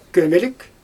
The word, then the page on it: kömElik: Xyzyl